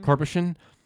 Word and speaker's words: Karpyshyn: I can never remember how to say his last name. – Karpyshyn. – It's like Karpyshyn.